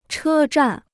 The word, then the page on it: 车站: chē zhàn